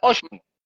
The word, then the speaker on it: ótimo: Oshimo se escribe O-T-I-M-O, Oshimo, se escribe ótimo y se pronuncia Oshimo, que es como decir chévere, buenísimo.